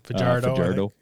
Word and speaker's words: Fajardo: Fajardo.